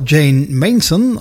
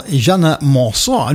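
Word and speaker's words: Jeane Manson: En daarvoor een zangeres die de naam op twee manieren kan uitgesproken worden. Ofwel Jane Mason, oftewel Jeanne Monson. En hoe komt dat? Jane Mason was eigenlijk een Amerikaanse, maar ze had succes in Frankrijk en ze zong in het Frans.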